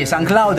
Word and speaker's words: SoundCloud: Voi sapete che c'è questo new media, social media, che si chiama SoundCloud, l'ho detto bene? – Sì, SoundCloud, e noi siamo presenti anche su questa interfaccia.